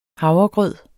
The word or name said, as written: havregrød